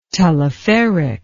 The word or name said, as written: teleferic